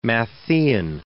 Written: Matthean

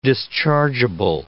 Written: dischargeable

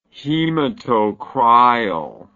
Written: hematocryal

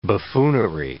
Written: buffoonery

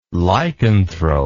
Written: lycanthrope

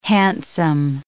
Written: handsome

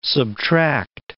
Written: subtract

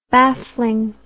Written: bafflingness

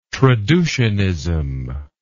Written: traducianism

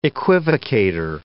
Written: equivocator

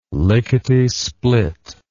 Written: lickety split

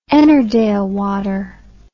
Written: Ennerdale Water

